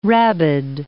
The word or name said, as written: rabid